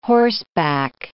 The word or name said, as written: horseback